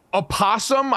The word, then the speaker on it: opossum: You were sleeping mere feet from where a sleeping opossum, I guess we have to pronounce the O. That's something that's been new to me.